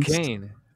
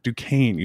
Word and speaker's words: Duquesne: Uh, how do you pronounce it? Du- Duquesne. – Duquesne. – Duquesne University. Yeah, it doesn't look like it, but Duquesne.